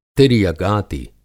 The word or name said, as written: तिर्यगाति